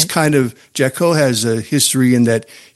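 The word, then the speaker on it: Jacot: Jaco,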